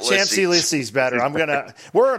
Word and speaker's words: Champs-Élysées: How to pronounce with the typical French pronunciation, Champs-Élysées. Champs-Élysées. – Champs-Élysées. – The avenue of the Champs-Élysées. Champs-Élysées. – It sounds like chomps. I chomp my Élysées. – No, Champs-Élysées.